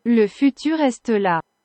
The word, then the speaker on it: Le Futur Est Là: What Google Translate pronounces the album name to? – Le Futur Est Là.